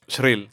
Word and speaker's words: Shrill: Shrill.